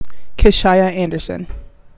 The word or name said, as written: Kishyah Anderson